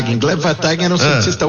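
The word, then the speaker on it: Gleb Wataghin: Gleb Vatagin. – Gleb Vatagin.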